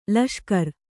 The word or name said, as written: ಲಷ್ಕರು